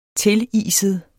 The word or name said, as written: tiliset